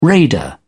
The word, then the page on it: raider: British English